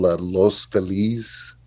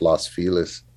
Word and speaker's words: Los Feliz: To be specific, it's a part of LA called Los Feliz, or, you know, you'll hear it pronounced Los Feliz.